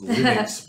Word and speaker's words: Linux: It's Linux because that's how Linus Torvalds say it. Potato. – That's how he pronounced it. – Yeah, so it's not pronounced the same as his name.